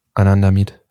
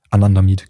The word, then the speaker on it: Anandamid: Habe ich es richtig gesagt? – Anandamid. – Anandamid, genau.